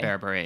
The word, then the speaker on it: Berbere: Berbere. Yeah. – Yeah. – Correct pronunciation.